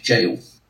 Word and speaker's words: gaol: That is how to pronounce it, ladies and gentlemen. It's an old English spelling. It might be G-A-O-L, but it's pronounced jail.